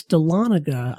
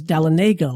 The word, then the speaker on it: Dahlonega: It's Dahlonega. I always thought it was Dahlonega, like Talladega. – Right. And it's not.